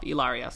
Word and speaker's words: Hilaria: And these are just the facts as they are presented, many of them presented by Hilaria herself. Ilaria, sorry. She corrects people and says the H is silent, so I'm not trying to be offensive. It is Ilaria.